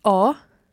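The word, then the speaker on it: ho: O. – You have. – I. – He or she has. – A. – Now, in each of those words, there's a silent H.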